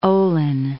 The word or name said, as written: Ohlin